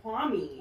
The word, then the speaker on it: Kwame: First of all, I say his name is Kwame because I feel like that's how it's supposed to be said. – He says Kwame. – Oh, Kwame. Okay.